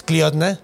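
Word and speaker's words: Clíodhna: Que no, seguro lo estoy pronunciando mal, pero se escribe C-L-I-O-D-H-N-A. – Cliodna. – Entonces, yo creo que sí es, pues, Cliodna.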